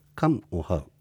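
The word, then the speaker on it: カㇺオハウ: カンオハウと言いますが、ムはですね、はっきりとムを発音しないというか、ちょっと飲み込むような発音ということで、これは少し難しくなります。いきます。カンオハウ。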